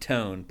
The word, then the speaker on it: Toan: Yeah, the main character is named Tone. But it's spelled T-O-A-N.